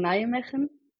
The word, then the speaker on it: Nijmegen: Die Holländer sagen Nijmegen. Jetzt weiß ich natürlich, die Holländer verdrehen jetzt wahrscheinlich die Augen, so wie ich es ausgesprochen habe. Das ist das beste Holländisch, was ich so rauskriege. Auf den Schildern in Deutschland steht Nimwegen. Ich habe versucht, mir anzugewöhnen, auch Nijmegen zu sagen.